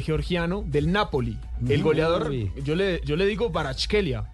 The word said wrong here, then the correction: Kvaratskhelia: Vicha Barachkelia. – Karabaskelia. – Vea cómo se pronuncia. – Vicha Kvaratskhelia. – Kvaratskhelia. Y es la voz de él, porque tanta gente le preguntaba que él mismo tuvo que aclarar.